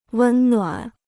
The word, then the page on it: 温暖: wēn nuǎn